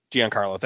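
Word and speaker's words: Gian-Carlo: It was a Giancarlo Coppola? – Giancarlo, thank you.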